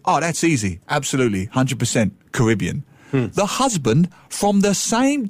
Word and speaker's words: Caribbean: Caribbean.